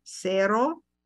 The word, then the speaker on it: cero: The number zero is spelled C-E-R-O and it's cero.